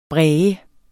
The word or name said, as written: bræge